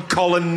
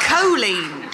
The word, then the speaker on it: Colleen: Did you just call Colleen Nolan Colin Nolan? – No, she called her Colleen. Colon Nolan. – As in Colin Powell.